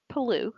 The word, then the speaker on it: Palou: Palou with Team Go. – Which is, yeah, G-O-H, but it's hilarious.